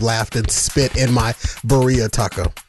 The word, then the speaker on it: birria: Let your Mexican side come forth. – Birria. – What? – Birria. – Birria? – Birria. – It's spelled B-R-I-A, right? – No. – It's spelled B-I-R-R-A. – B-I-R-R-A. – If you wanna white person it up, it's birria.